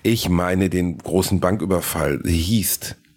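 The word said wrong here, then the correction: Heist: Hießt, heißt, heißt wird es ausgesprochen, heißt.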